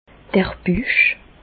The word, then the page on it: d'r Büch: Bas Rhin; Reichshoffen